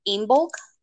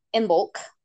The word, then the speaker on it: Imbolc: So the internet says to pronounce it Imbolc, but it's spelled Imbolc.